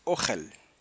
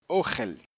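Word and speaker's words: ohel (אכל): Pero sí sé que significa alimento, comida, y en hebreo lo que dice es ohel. Se escribe con alef, kaf, lamed, ¿ok? Alef, kaf, lamed, ohel.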